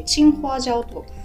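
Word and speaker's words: 青花椒: 青いファージャオと書いてチンファージャオと言うんですけれども、はい、でもう一つが赤いファージャオで、ホンファージャオと言います。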